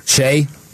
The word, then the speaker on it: Shai: Shai? – Oh, is that how you say his name? – Yeah.